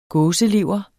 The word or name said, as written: gåselever